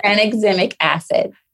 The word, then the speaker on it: tranexamic acid: Tranexamic acid.